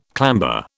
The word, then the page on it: clamber: British English